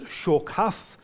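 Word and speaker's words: shokath (שחת): They are the Hebrew word pronounced shokath, and it means corrupt.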